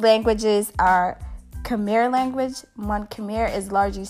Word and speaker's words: Khmer: That means hello in Cambodian, in the language of Khmer. Khmer, Khmer, Khmer. K-H-M-E-R.